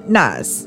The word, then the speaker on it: Nas: How do you pronounce his name? Lil Nas? – Lil Nas X. – It's Lil Nas. – Is it Lil Nas or Lil Nas? – Nas. – All right. So because if you go according to old school Nas, N-A-S, it's Nas.